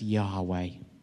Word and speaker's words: Yahweh: And over thousands of years, many have come to pronounce that as Yahweh.